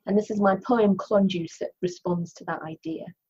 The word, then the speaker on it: Klonjuze: It's spelt, she tells me, K L O N J U Z E, Klonjuze.